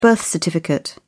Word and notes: birth certificate: British English

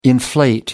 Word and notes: inflate: American English

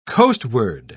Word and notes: coastward: British English

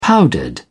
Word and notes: powdered: British English

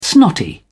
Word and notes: snotty: British English

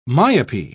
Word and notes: myopy: British English

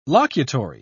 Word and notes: locutory: British English

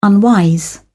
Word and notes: unwise: British English

